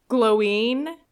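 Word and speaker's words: Glóin: But it's, I think, technically supposed to be pronounced Gloin because there's an accent on the I, and there's been a lot of back and forth.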